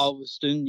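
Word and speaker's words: Ulverstone: Obviously, you're based in Ulverston, is it? Is that how it's pronounced? – Yeah, Ulverston. Yeah, that's correct.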